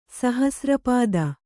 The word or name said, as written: ಸಹಸ್ರಪಾದ